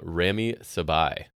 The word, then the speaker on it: Rami Sebei: It is, I might say this wrong, Rami Sebei, S-E-B-E-I.